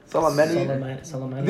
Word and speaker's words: Soleimani: Soleimani.